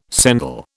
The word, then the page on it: sendal: British English